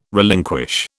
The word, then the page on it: relinquish: British English